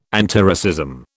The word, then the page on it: antiracism: British English